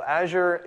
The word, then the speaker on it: Azure: So Azure is sort of Microsoft's, it rhymes with Badger.